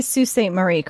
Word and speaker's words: Sault Ste. Marie: Yeah, no, he did say Sault Ste. Marie correctly, so I guess I gotta give him that.